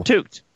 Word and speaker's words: toque: It's called a fucking toque. – It's not called a toque anywhere else. – T-O-U-E. It's spelled like it sounds.